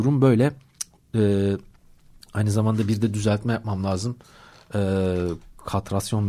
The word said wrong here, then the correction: kastrasyon: mevzusu. Katrasyon değildi o. Kastrasyon. Bir tekrardan bakmam lazım. Oldukça fazla karıştırdım cümlelerden bir tanesi, kelimelerden bir tanesi. Kastrasyon aslında doğrusu. Ben katrasyon olarak sürekli telaffuz ediyorum bunu.